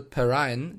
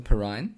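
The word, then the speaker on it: Perine: Ich habe gelernt, dass äh, LaMichael Perrine nicht Perrin ausgesprochen wird, sondern Perrine.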